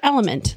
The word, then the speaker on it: LMNT: Enter Element, that's spelled L-M-N-T, a tasty electrolyte mix with everything you need and nothing you don't.